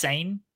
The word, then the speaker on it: Saiyan: Isn't it Super Saiyan? Saiyan? How are you supposed to say it? Super Saiyan? Yeah. Is it just Saiyan? Like that? Yeah. Oh, okay.